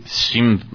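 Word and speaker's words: Schism: Que se dice Shim. – Se llama Shim.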